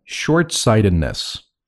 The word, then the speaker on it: short-sightedness: Next one, short-sightedness. – 注意这里的读音的时候啊，中间那个的要读得很轻啊。